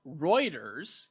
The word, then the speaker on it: Reuters: Reuters, because that is actually how you say it.